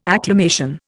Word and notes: acclamation: Indian

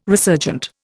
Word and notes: resurgent: Indian

